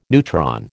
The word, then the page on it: neutron: American English